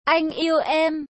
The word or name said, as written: anh yeu em